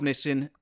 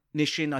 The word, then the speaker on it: Nissin: Nissin, obviously, long-time admirers of Nissin. Nishin, I think, is how it's pronounced, in fact.